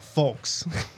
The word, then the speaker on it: Fulks: F-U-L-K-S. – Oh, folks.